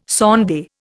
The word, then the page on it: sonde: Indian